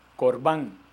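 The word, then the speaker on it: Corbán (קרבן): Corbán.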